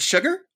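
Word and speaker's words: Chigurh: I just couldn't pronounce his last name. Is it Sugar? – Yeah.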